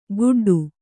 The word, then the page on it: ಗುಡ್ಡು: kannada